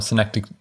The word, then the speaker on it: synecdoche: No, synecdoche. Go ahead. Synecdoche. – It's a thing. – I am probably saying it wrong. Synecdoche. Yes, you are correct. I said it incorrectly.